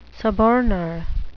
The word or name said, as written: suborner